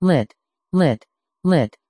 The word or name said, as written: lit